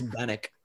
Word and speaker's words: Bannock: We were doing Cool Runnings, you said like Darice Bancock. I'm like, his last name is Bannock.